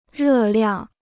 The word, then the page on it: 热量: rè liàng